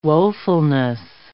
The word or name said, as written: woefulness